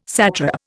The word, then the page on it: satrap: Indian